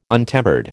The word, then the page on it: untempered: American English